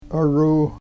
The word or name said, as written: oruu